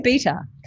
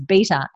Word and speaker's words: beta: But the word beta comes from the Greek word vita, which is the letter B, and so I know the real way to pronounce it is beta and not beta, and I have told them that.